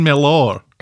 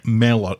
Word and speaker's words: Mellor: You're saying Mellor. You're putting the emphasis on the or. Mellor. – Mellor. – Just say Mellor. Imagine it's spelled M-E-L-L-E-R.